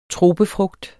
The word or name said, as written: tropefrugt